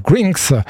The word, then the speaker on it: GRNXX: Alors, ça s'écrit G-R-N-X-X. Ça se prononce donc Grinx.